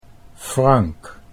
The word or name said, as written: Frank